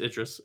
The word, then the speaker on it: Idris: I said his name wrong, but... – It's Idris. It's cool.